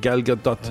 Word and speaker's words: Gal Gadot: Gal Gadot.